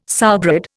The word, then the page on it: sowbread: Indian